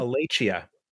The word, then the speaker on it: Alachia: I always did Alachia with a hard. – Right. – Yep. Alachia. – Okay. Like school, you know, S-C-H.